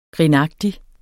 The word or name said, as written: grinagtig